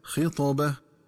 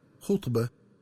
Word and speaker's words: خطبة: وكذلك ينبغي التنبه للنطق الصحيح لكلمة خطابة، فتقرأ بكسر الخاء هكذا خطابة، ومن الخطأ قراءتها بضم الخاء هكذا خطبة.